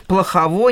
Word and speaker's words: Плохово: Плохово, недоброво. Но чтобы э, их а, ограничивать или отличать от соответствующих слов, да, они имели ударение на последний слог.